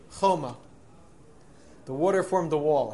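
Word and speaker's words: choma (חומה): Right, it's pronounced choma, but it's spelled as if it says chema.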